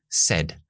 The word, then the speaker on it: said: Muy importante, este verbo decir en pasado se pronuncia como una E. Said, said. Pero ¿qué pasa aquí?